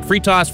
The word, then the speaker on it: Freitas: What do I say, dude, Nick? What's the right way to pronounce it? – Technically, it's pronounced Freitas, but my drill sergeant Freitas taught me to respond to anything close. – all right. Freitas is the right way to say it.